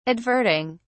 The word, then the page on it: adverting: British English